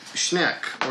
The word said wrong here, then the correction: Schenck: or Schenck. I'm sorry, I'm saying it wrong. Joseph M. Schenck, producer.